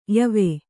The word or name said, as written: ಯಾವ